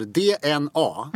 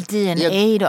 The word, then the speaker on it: DNA: De är tillbaka på banan och har gjort ett album som heter DNA. – Mm. Eller DNA då. – Åh, är det så det uttalas? Okej då. – Det beror på.